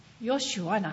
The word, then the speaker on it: ヨシュア: そして、この主は救い、イエスというヘブル語の読み方はヨシュアなんですね。